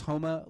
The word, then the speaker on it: Houma: I had to email the guy back because I wasn't sure how to pronounce this. It is Houma, Louisiana.